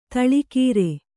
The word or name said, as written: ಧಾಳಿಕಾರ